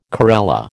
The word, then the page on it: corella: American English